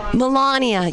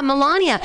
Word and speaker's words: Melania: Melania. Yeah, Melania.